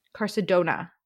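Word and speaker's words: Carsodonia: Don't make them with an N-I-A ending. – Yeah, no, like. – Wait, so how do you pronounce it? – Carcedona. – Yeah. According to the pronunciation guide.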